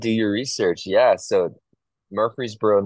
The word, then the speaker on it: Murfreesboro: And so you're originally from Murfreesboro, Tennessee. Is that correct? – That's correct. You pronounced it correct as well.